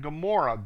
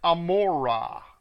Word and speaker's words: Gomorrah: Now, in here it's Sodom and Gomorrah. That's not how it's pronounced. If you read the Hebrew, it's Sodom and Gomorrah, not Gomorrah. And